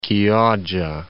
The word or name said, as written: Chioggia